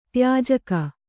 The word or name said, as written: ತ್ಯಾಜಕ